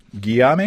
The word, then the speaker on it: Guillaume: Would it be Guillaume? G-U-I-L-L-A-U-M-E. Guillaume? Guillaume? – Yeah, I'd say Guillaume.